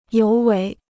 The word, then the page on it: 尤为: yóu wéi